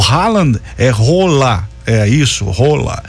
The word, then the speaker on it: Haaland: Os atacantes de 25 anos, Vini Júnior e o Erling Rola. É, você pronuncia Rola, não tem nada de Haaland, Rona, é Rola, o correto é Rola.